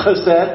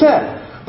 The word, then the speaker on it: chesed: It's a concept called chesed. I'm mispronouncing it because it's that nice H, that chesed. I don't want to spit, though. But it's chesed.